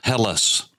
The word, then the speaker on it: Hellas: Because he had come from the land of Hellas, that is E-L-L-A-S, with a rough breather on the front, a little backwards apostrophe, it would have been pronounced Hellas with an H.